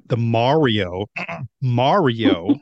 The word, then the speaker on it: Mario: So I'll have to get his views on it because he is, like, the Mario Mario is how it's pronounced, the Mario enthusiast.